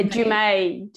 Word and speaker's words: Dumais: Yeah, Dumais.